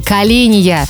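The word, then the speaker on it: каления: Ну а вы отвечаете, шеф меня до белого колена доводил. – Вот оно что. Ну, конечно, правильно говорить, до белого коленя.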